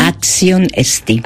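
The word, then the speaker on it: Axion esti (Άξιον Εστί): Axion esti. – Axion esti.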